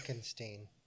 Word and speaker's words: Frankenstein: Like, he is so mad when he's called Frankenstein. Around. Yeah. – Just pissed off face. – Yeah. – It's pronounced Frankenstein.